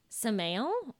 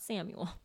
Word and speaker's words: Samael: Samael, or Samael, which means the venom of God. I looked it up. Out of all of the YouTube pronunciation clips I watched, it was not Samuel.